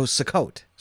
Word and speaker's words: Sukkot: My suit coat? – No, your suit coat. – Oh, Sukkot. – Sukkot. – You got the emphasis on the wrong syllable.